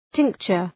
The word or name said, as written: tincture